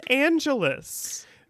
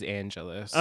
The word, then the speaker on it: Angelus: And because Angel has said it, I can only assume that the correct pronunciation is Angelus. – Well, that would make sense because Spike says Angelus.